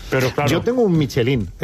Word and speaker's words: Michelin: Oye, Michelin, ¿cómo se pronuncia definitivamente? – Michelin. A ver, aquí en la Península Ibérica hacemos Michelin.